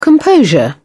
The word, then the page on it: composure: British English